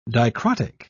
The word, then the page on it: dicrotic: British English